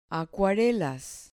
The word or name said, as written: acuarelas